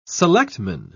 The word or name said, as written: selectman